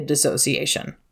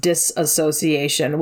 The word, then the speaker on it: dissociation: As as somebody who wants that and believes that's what's best for our society, yes, there should be a dissociation. It's sometimes commonly mispronounced disassociation, which soapbox, it's technically not correct to say disassociation, but if it helps you to conceptualize what dissociation means, that it is a disassociation from something, Cool.